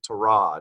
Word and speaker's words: Tyrod: He told everybody, by the way, I actually pronounce it Tyrod.